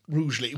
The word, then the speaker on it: Rugeley: Rougeley, which you've learned how to pronounce. – I've learned how to pronounce that very recently. – R-U-G-E-L-E-Y, those of you who are taking notes. – Is that correct? – Yeah.